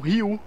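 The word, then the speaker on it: Ryu (隆): Então, é, o primeiro caractere chinês usado no nome Takashi pode ser pronunciado como Ryu.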